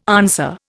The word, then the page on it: answer: Indian